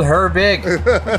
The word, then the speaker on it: Herbig: It's spelled Herbig. Exactly like it sounds.